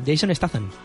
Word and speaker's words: Statham: Jason Statham.